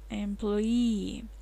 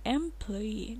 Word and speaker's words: employee: Employee. Либо employee, либо employee, ну, ударение на и в конце. Employee.